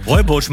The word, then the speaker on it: Rooibos: Rooibos? Habe ich noch nie gehört. – Rooibosch, Mann. – So schreibt man. So schreibt man doch nicht Rooibosch. – Doch, so schreibt man Rooibosch. Das ist Rooibosch.